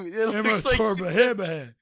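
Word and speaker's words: Imatorbhebhe: Imaturbabihe. No, that's how you say it. That's how you spell his name. Imaturbabihe.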